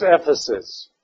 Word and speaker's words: Ephesus: The first one is Ephesus.